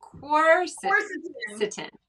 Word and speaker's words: Quercetin: Quercetin.